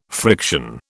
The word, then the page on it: friction: British English